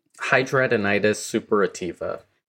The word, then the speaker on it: hidradenitis suppurativa: And she speaks to Hidradenitis suppurativa. Did I say that right? – Hidradenitis suppurativa.